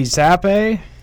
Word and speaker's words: Zappe: And then I got at 67, I have Detroit taking Bailey Zappe. Is that how you say it? – Mm-hmm.